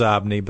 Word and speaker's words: Xobni: It's pronounced Zobni, but spelled with an X.